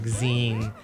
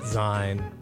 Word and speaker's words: zine: And there's a note, it says zine is pronounced like zine, as in ween with a Z instead of a W.